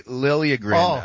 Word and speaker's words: Liljegren: Timothy Liljegren. Oh, it's actually Liljegren.